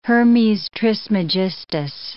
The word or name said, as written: Hermes Trismegistus